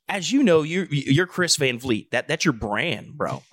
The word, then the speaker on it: Van Vliet: Like people are like Chris Van Vliet. Yeah, I get that all the time. – I didn't think about that. – Thank you for pronouncing it correctly. – Van Vliet.